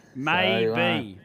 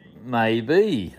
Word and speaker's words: Maebee: It's spelled M-A-E-B-E-E. – Maybe. – Maybe. That's correct.